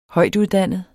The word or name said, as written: højtuddannet